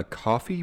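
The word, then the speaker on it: Ko-fi: So he's got something called a, a coffee page. It's K O F I. – Oh, okay. – And he says that he thinks it's pronounced coffee, but I don't know.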